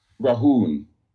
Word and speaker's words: Rhaune: I spell it for you. R-H-A-U-N-E. Rahun.